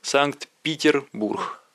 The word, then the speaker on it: Санкт-Петербург: Именно вот так, с голландским ударением Санкт-Петербург.